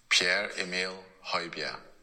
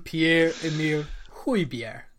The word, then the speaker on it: Pierre-Emile Højbjerg: Pierre-Emile Højbjerg. – There we go. Perfect. – Yeah. So I've had that. I've studied it. – Studied it. I love it. – Pierre-Emile Højbjerg.